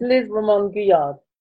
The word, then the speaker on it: Liz Remande-Guyard: It's Liz Remande-Guyard.